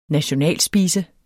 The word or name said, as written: nationalspise